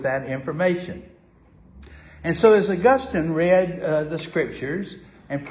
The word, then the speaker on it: Augustine: Howsomever, it is pronounced differently, and if you're talking about the saint, it is Augustine, not Augustine.